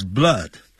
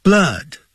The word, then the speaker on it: blood: When he sees blood, 他看到血液的时候他就整个就昏倒。 Blood, 不要念成 blood, 肚子饿的饿 ,blood, 饿像倒 V 状的那个符号要去了解。